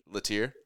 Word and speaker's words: Latteier: How do you pronounce it? Latier. Okay.